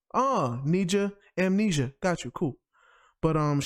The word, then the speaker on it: Nija: Now, for me, I got this artist named Nija. I believe that's how you say her name, N-I-J-A.